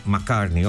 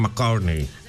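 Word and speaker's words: Carney: Η φίλη μου η Παρασκευή με διορθώνει και μου λέει το όνομα του Καναδού Πρωθυπουργού είναι Μακάρνεϊ. Εγώ το είπα σαν Μακάρνεϊ, όχι, είναι Μακάρνεϊ.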